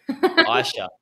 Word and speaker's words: Aisha: Did I get the pronunciation correctly then? – It's Aisha. – Aisha. Aisha.